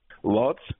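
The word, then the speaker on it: Łódź: is it pronounced Lodz? – Lodz. – Lodz, okay.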